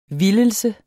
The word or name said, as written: vildelse